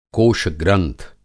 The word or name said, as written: कोषग्रंथ